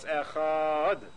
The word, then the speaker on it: Echad: So it's Echad. You stretch the last syllable.